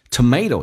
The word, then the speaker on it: tomato: 那么 going 稍微的饱满一点， I'm going to make tomato soup. Tomato 这个单词美式发音发 A，那么英式发音发成 tomato。 Tomato 也许美国人觉得英国人有点像骂人，所以把成变成 tomato。